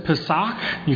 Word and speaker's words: Pesach: The word is Pesach. You got to say it with some phlegm to pronounce it right.